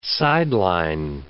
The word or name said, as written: sideline